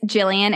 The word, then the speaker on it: Gillean: On social media, it's Jillian, and Jillian is spelled G-I-L-L-E-A-N.